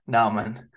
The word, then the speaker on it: Naaman: Actually, I think it is Naaman. Google pronunciation says Naaman.